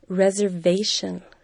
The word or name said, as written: reservation